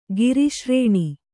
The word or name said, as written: ಗಿರಿಶ್ರೇಣಿ